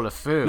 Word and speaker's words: LeFou: I think that the presentation of Luffy, is it Luffy? I can never remember how to pronounce his name correctly. Luffy. – Lefou. Oh, LeFou.